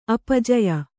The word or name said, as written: ಅಪಜಯ